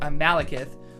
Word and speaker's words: Malekith: All of the one-shots and side issues tie into this big battle where all the Marvel heroes are coming together to fight Malekith, which I just saw in Thor Dark World.